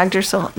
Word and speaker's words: Yggdrasil: Yeah, Yggdrasil, yeah.